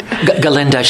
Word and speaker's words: Galinda: Galinda. She drops the G is silent, yes.